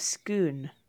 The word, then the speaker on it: Scone: Because I want to say Stone of Scone. – I think it's Stone of Scoon. – Scoon. – It's Scoon, but you make it more Scottish, I think.